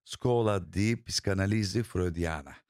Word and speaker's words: Scuola di Psicanalisi Freudiana: Daha doğrusu orada da tam olarak belki telaffuz edemiyorum ama Scuola di Psicanalisi Freudiana. – Harika, doğrudur.